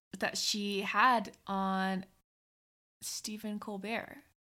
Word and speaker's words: Colbert: And she was featured on the show, Stephen Colbert. – Colbert. – Correct? – Yeah. – Okay. – Once again, Cressy adding syllables.